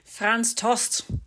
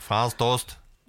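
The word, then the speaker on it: Franz Tost: Frans Toast. – ja, så ska det uttalas ja. Frans Toast.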